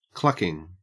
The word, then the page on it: clucking: Australian English